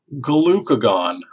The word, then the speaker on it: glucagon: Question is, what organ produces insulin and, let me pronounce this correctly, glucagon? I'll spell that for you. G-L-U-C-A-G-O-N.